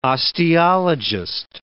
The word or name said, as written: osteologist